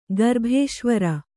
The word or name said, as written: ಗರ್ಭೇಶ್ವರಿ